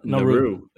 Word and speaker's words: Naru: How do you say it? – That's like Niru. – Naru. N-A-R-U. – Naru. Yeah.